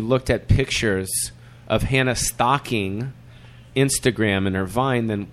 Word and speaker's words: Stocking: It's Hannah Stocking. So that's Stockings. A singular stocking.